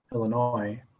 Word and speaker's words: Illinois: The S is silent. Illinois.